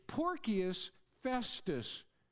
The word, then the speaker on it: Porcius Festus: And his name is Porcius Festus. That's how they say it in Latin.